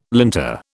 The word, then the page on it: linter: British English